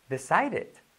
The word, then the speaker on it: decided: Decided. Decided. La D como una R suave, ¿vale?